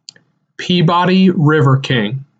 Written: Peabody River King

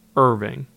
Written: Irving

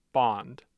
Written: bond

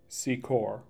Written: Secor

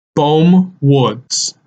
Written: Bohm Woods

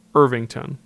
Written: Irvington

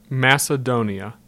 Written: Macedonia

